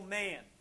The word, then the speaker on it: Mann: You know, like Daniel Mann. My last name is spelled M-A-N-N.